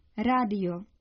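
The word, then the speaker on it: rádio: La única diferencia es que los checos alargan la pronunciación de la primera sílaba y marcan el alargamiento con el acento gráfico, radio.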